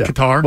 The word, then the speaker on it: Qatar: Qatar. Am I saying that right? – Sure. – Yeah. Qatar, whatever. – Yep. Cutter, whatever.